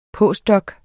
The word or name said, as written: postdoc